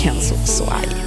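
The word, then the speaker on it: Kenso Soai: Så kan jag också få utrymme att rätta mitt uttal här då. Henri Kagan skulle det vara från Frankrike som vann. Och Kenzo Soai. Jag sa väl något helt annat förut.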